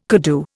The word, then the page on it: koodoo: Indian